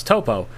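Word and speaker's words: Toppo: And so I was talking about God of Destruction topo. Actually, it's God of Destruction top? No, it's topo.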